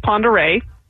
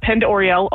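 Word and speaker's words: Pend Oreille: So our county is Ponderay, but we hear Pend-Oriel a lot. That's the only one I've ever heard on the mispronunciation is Pend-Oriel, because that's the way it's spelled.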